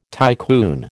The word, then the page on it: tycoon: American English